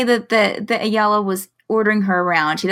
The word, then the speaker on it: Eyala: Ayala. Yeah, E-Y-A-L-A.